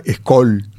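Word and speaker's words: Skäl: Es Scholl, se pronuncia Scholl, uh-huh, hay una O. Sin embargo, si lo ves escrito, ves que es una S-K-A-L. Pero si te fijas, la A tiene los dos puntitos arriba, la diéresis.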